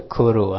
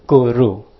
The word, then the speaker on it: guru: 不是 Kuru 啊，这是念 Guru，个的发音。这个是真正的所有的那个罗马拼音的发音，正确的罗马发音呢，Guru，如果的如，不是路啊，Guru 这个。